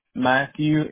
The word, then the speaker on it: Matthew: Matthew, M-A-T-T-H-E-W, and my first name is spelled C-O-N-N-E-R.